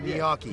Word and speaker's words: gnocchi: Gnocchi.